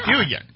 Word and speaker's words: Dillion: Dillion. – Like the big number, only with a D.